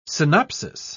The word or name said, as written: synopsis